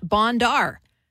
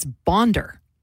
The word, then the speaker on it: Bondar: It's not Bondar. That's not how you say Roberta's last name. It's Bondar. – Well, it's close. You're close.